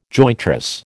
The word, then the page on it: jointress: American English